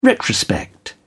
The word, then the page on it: retrospect: British English